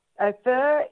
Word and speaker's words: Ophir: Ophir is, I think I'm saying it right, it could be Ophir. But anyway, let's say Ophir, O-P-H-I-R.